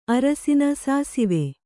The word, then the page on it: ಅರಸಿನ ಸಾಸಿವೆ: kannada